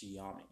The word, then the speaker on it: Xiaomi: Okay, I think it's pronounced Xiaomi.